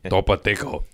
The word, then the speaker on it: Topo Chico: Topo Chico is so good. – Did I say that right? – Topo Chico.